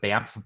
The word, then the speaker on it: BAMF: In terms of books, there's something called the Banff Bible. It's spelled BAMF.